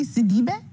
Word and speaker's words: Sidibe: By the way, is it Sidibe or Sidibe? – It's actually Sidibe. It's Senegalese and it's got accents that I don't use, but I usually say Sidibe because Americans need things to rhyme, so I say it's Sidibe, like city by the bay.